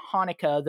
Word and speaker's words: Hanukkah: Chanukah. It as Chanukah. If you pronounce it as Chanukah, that's just wrong. That sound doesn't exist in Hebrew. It just doesn't.